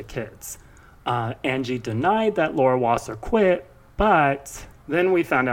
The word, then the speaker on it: Wasser: So what started all this was there was a rumor that Angie's lawyer, the legendary Laura Wasser, is that how you say it? I would say so.